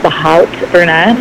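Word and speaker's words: Haute Brunette: It's spelled THEHAUTEBRUNETTE. So The Hot Brunette.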